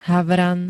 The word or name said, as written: HAVRAN